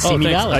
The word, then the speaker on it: Simi Valley: Leah Garner also placed, did I say it incorrectly? – No, you did. You said, I was going to say, I was going to give you props saying it right, Simi Valley.